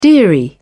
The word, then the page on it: dearie: British English